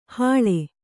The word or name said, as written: ಹಳೆ